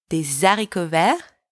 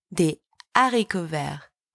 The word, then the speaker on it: des haricots verts: So you don't say des haricots verts. You say des haricots verts. Do you hear the difference? Des haricots verts. It's wrong. Des haricots verts. That's right.